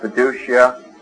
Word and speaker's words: fiducia: We usually pronounce that fiducia, although I suppose if we were literalists, we'd give it a better Latin pronunciation, but we'll just stay with the common way of speaking of it, fiducia or trust.